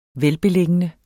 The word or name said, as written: velbeliggende